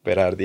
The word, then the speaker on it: Berardi: Did you want to chat to us I'll pronounce this right, Domenico Berardi? – Berardi, yeah.